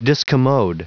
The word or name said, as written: discommode